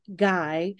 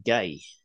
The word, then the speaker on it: GAI: glad you pronounced that because I would have pronounced that gay. Guy. G A I.